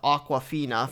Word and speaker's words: Awkwafina: I mean, obviously going with Nick Cage and then Ben Schwartz, and then you have her name is pronounced Awkwafina for Quincy.